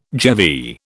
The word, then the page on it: GeV: British English